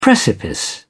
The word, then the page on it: precipice: British English